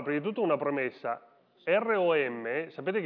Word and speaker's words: ROM: Ma in inglese ROM e ROME, che sta per Roma, hanno la stessa pronuncia, perché entrambe si pronunciano ROM.